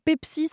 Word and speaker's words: Peppsy: Et cette application euh, que vous avez donc euh, lancée fin décembre s'appelle Pepsi, ça s'écrit P-E-D-P-S-Y.